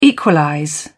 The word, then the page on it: equalize: British English